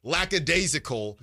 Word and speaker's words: lackadaisical: But ultimately, I just don't think that you come out lackadaisical. – Yes. – Again. Okay, gotta be real careful to pronounce that correctly. Because my mom texted me last time. She's like, "There's no S.